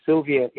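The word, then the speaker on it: Sylwia: Makowska is M-A-C-K-O-W-S-K-A. And it's Sylvia is pronounced Sylvia, but it's S-Y-L-W-I-A. S-Y-L-W-I-A.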